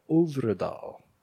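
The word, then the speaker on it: Øvredal: Is being directed by Andre Uvredal. – Whatever the thing that looks like a zero, however that's pronounced. – I believe it's Uvredal.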